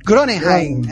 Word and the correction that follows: Groningen: é assim que pronuncia? Rafael, é assim? É. – É, Groningen.